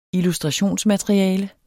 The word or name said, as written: illustrationsmateriale